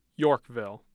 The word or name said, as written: yorkville